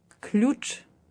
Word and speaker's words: Ключ: Ключ.